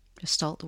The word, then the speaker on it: Gestalt: Gestalt. Gestalt, where it's the whole, it's not a binary.